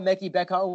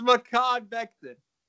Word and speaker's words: Mekhi Becton: I maybe thought Mekhi Becton was the pick here, but I think even the Giants are smart enough right now.